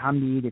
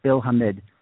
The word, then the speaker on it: Hamid: And Stefan's going to be, I think right now, we know we got Brad Guzan, we got Bill Hamid, we got, and that's how I understand he wants to pronounce his last name, not Hamid, it's Bill Hamid.